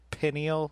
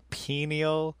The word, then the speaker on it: pineal: Lagan, it's pronounced pineal. – Pineal. – Not pineal.